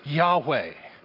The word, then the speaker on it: Yahweh: That is a stand-in for this Hebrew word, which is pronounced Yahweh.